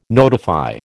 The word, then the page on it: notify: American English